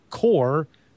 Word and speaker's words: QOR: It's called the Core 32.